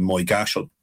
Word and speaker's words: Moygashel: And I suppose the worst example of this occurred in Moygashal, which is a village in County Tyrone, which it's spelled Moygashel.